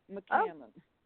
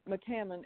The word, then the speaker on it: McCammon: No, I go by Irena McCammon Scott, or just Irena Scott. – Irena Hammond Scott, was that Hammond your maiden name? – No. Yeah, McCammon.